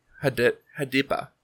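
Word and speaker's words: Hadippa: And then they also do the title song, Dil Bol Hipiada. – Cool. – So I definitely said that wrong. Hadipa. There we go.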